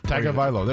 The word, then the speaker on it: Tagovailoa: How do you say it? – I've been practicing Papanastas. Tagovailoa. I don't know. Tagovailoa. – Tagovailoa. There you go.